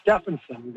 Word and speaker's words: Stevenson: He was an Icelandic fellow from Manitoba, and his name was actually pronounced Stephenson because he was Icelandic.